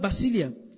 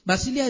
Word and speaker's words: basileia: And that word kingdom is the word basilia in the Greek. Basilia is spelled B-A-S-I-L-E-I-A.